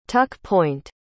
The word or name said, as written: tuck-point